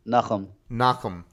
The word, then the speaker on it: Nachum: And I'm going to learn how to say your name. One more time. – Nachum. – Nachum. – Great. You got it.